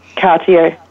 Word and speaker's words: Cartier: Can you pronounce it for me? – Cartier, yep, correct.